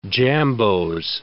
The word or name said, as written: jambeaux